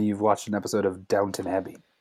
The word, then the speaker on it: Downton Abbey: I thought it was Downtown Abbey. – And they just mispronounced it? – I guess I mispronounced it.